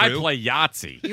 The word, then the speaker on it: Yahtzee: It's weird that Yahtzee rhymes with Nazi.